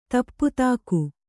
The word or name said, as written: ದೀಪ್ತಿಕೆ